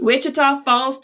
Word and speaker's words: Wichita Falls: So Kellee is from Wichita Falls, Texas. And did I say that right, Kellee? – Yes, you did. Perfect. – Wichita Falls, Texas.